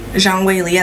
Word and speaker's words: Zhang Weili: How do you pronounce Lee's name? – Zhang Weili.